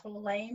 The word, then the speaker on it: Tolle: It looks like Eckhart Tolle. – Tolle, maybe. – Yeah, Tolle, The Power of Now.